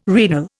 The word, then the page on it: renal: Indian